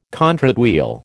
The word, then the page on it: contrate wheel: American English